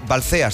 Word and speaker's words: Balcea: Oye, pues mira, me gusta tanto que nos sintonices cada día, que sintonices cada día, Cadena Dial, desde Rumanía, desde Balcea. ¿Se pronuncia así? – Sí.